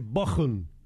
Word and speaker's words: Bochum: De vuelta Raúl desde Bochum, y me dice, bien pronunciado, con CH, entre dos vocales se pronuncia como J.